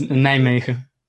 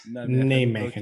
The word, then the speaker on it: Nijmegen: It's Nijmegen. – Nijmegen in the Netherlands. – Okay. Nijmegen.